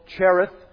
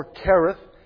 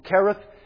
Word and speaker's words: Kerith: And God led him all the way back past where he was from to the brook called Cherith, or Kerith, as it is perhaps more correctly pronounced from the Bible, would be Kerith, but many of us look at the CH and we're happy to say Cherith, and that's how we'll pronounce it for our benefit.